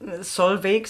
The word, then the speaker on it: Solveig: also ich halte gerade das Printmagazin hoch, ähm, namens äh, Sol, Solveig oder Solvay? – Solveig sagen wir okay. – hier in Deutschland. Es wird eigentlich auch ganz anders ausgesprochen, nur dann würde es hier keiner mehr verstehen.